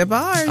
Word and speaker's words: DeBarge: Teacher, actress, and singer Sonia McCarter's final song is Rhythm of the Night by DeBarge. Is that how you say it? – DeBarge.